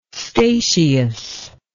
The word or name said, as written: statius